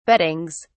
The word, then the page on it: beddings: British English